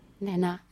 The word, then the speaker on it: Naná: Así es, con acento en la A, ¿no? Naná. – Naná, perfecto.